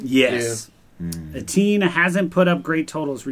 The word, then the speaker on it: Etienne: Etienne at the Texans, and Nick Chubb's at the Commanders.